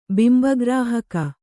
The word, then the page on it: ಬಿಂಬಗ್ರಾಹಕ: bimba grāhaka